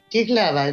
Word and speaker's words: Jihlava: Es Jihlava o algo así, no sé cómo se pronuncia. – Sí, Jihlava, efectivamente.